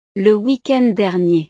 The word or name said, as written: weekend dernier